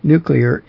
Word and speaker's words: nuclear: The other point, too, about nuclear is it's often mispronounced by some people, but it's nuclear. Nuclear is the proper pronunciation of that word, and the spelling indicates that also.